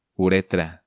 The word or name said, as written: Uretra